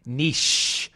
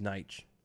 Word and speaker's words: Nietzsche: It's niche. Nope, not by American standards. – It's Nietzsche.